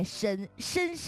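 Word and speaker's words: 莘莘: 但是呢深的这个字的正确读音就是念深，深深，这是一个叠词，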